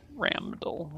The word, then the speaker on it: Ramdall: Ramdall with an M. You mispronounce his name and his eye twitches. – It's Ramdall with an M? – R-A-M-D-A-L-L. – Are you sure? It's R-A-N-D-A-L-L on my character sheet.